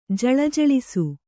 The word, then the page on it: ಜಳಜಳಿಸು: jaḷajaḷisu